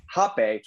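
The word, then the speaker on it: rapé: And so the exercise, we did a meditation the night before, and we did what's called Hape, actually spelled R-A-P-E, which is pretty aggressive, but pronounced Hape.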